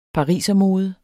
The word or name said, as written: parisermode